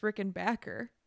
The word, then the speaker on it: Rickenbacker: I think it's the last one that Tom Petty did, but he um is talking about Rickenbacker ba- guitars, but he says, which I actually learned is it's pronounced Rickenbacker, and so like whenever that guitar is mentioned in my head, I just hear Tom Petty saying actually, Rickenbacker.